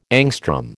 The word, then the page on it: angstrom: American English